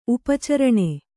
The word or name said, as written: ಉಪಚರಣೆ